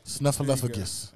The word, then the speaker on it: Snuffleupagus: Snuffleupagus.